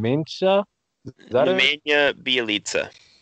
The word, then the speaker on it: Nemanja Bjelica: Nemanja Bjelica.